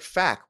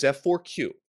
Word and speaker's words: F4Q: People are saying like, no, it's like fac, right? Like it's pronounced fac because it's supposed to sound like fac you. It's like, no, it is F4Q. That is what they have been called since they debuted in First Strike. That is how it's pronounced.